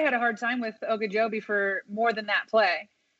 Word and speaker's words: Ogunjobi: Ogunjobi? – Ogunjobi.